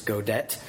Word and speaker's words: Godet: Godet, I believe that's how his name was pronounced. He's a Swiss Reformed theologian. I've heard Godet, I've heard many other pronunciations of his name, but I looked it up on Google pronunciation today to make sure, and I was told it's Godet.